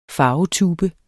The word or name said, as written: farvetube